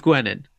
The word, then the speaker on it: Gwenin: Mr. Steve Gwenin. Am I saying that right? Gwenin? – Yeah, you are.